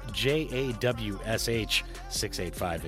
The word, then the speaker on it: Jawsh 685: And that's spelled J A W S H 685, in case you were wondering. That's Jawsh 685.